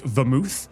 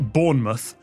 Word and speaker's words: Bournemouth: I love that you pronounced it like vermouth. It is unfortunately just Bournemouth because British English reduces every single vowel. – M-U-T-H sounds like mooth.